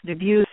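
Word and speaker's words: Dubus: his name is pronounced Debus, and what he always said was it rhymes with abuse.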